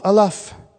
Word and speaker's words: aleph: And I'll just give you the first letter of the Hebrew alphabet, kind of, if you can't remember how to pronounce this, almost like an olive, aleph.